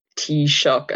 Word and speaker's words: Taoiseach: That's Taoiseach, pronounced Taoiseach and spelled TAOISEACH for any non-Irish listeners.